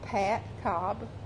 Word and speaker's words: Pat Cobb: Pat Cobb, P-A-T-C-O-B-B. – Barbara Lancaster, B-A-R-B-A-R-A, L-A-N-C-A-S-T-E-R.